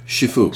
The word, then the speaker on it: Shifu: Now, Bill is, am I saying that right? Shifu? – Shifu.